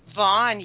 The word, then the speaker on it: Vaughan: I was in, is it Vaughan? Is that how you say it? – Vaughn, yes, I know Vaughn.